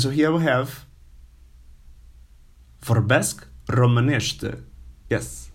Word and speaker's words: Romanește: Okay, and the word for the language Romanian is Romanește. Yes, you can repeat it. You can use like starting from the end. Ște, nește, mănește, Romanește. Romanește.